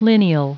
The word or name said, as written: lineal